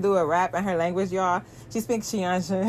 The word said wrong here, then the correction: Chinyanja: Is that how you say it? – Close enough. Chinyanja. – There you go. Nyanja for short.